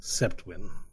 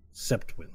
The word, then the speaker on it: Sjeptwyn: You may call me Septwin. It's spelled S-J-E-P-T-W-Y-N. Septwin. – Septwin. – Septwin Mayer. M-A-E-R.